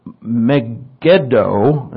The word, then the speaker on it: Megiddo: Dor, Tamakh, Megiddo, it's pronounced, and this place, Beth Shean.